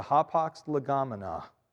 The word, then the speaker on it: hapax legomena: Okay, the ha pax legomena, that's Greek. It's spelled, in case you care, H-A-P-A-X, L-E-G-O-M-E-N-A, two words, ha pax legomena.